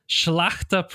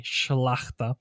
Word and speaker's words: Szlachta: Schlachta privileges. Yes, that is how you say that word. Yes. S-Z-L-A-C-H-T-A. Schlachta.